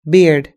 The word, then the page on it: beard: British English